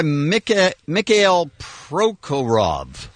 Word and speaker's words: Mikhail Prokhorov: Crazy Russian guy, Mikhail Prokhorov, I believe is how you say his name.